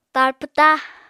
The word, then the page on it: tar pwta: Apatani